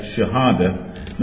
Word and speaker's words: Shahada: That's called Shahada. Is that the right pronunciation? Yeah, Shahada.